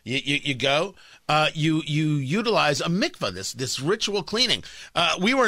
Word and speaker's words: mikvah: So they, in this synagogue, they built a tunnel, and the tunnel is connected to something called a mikvah, M-I-K-V-A-H.